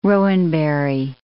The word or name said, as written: rowanberry